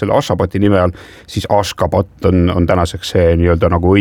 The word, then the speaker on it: Ashgabat: Jaa, selle pealinna tänapäevane õige kirjapilt ja hääldus on Ashgabat ja siis 1991.